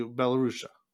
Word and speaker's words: Belarus: The same thing like he would say about the Belarusia. How do you say it in English? Belarus. Belarus.